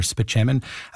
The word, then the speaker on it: Spaceman: Did you ever see him in 30 Rock where he plays Dr. Spaceman but you find out his name is pronounced Dr. Spitchemin.